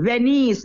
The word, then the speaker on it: Venies: Venice, V-E-N-I-E-S.